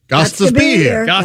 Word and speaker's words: Gostisbehere: No, S-H-A-Y-N-E. Okay. – Oh. – His last name gots to be here. G-O-S-T-I-S-B-E-H-E-R-E. How do you say that? – Do it. Spell it one more time. – G-O-S-T-I-S-B-E-H-E-R-E. – Got to be here.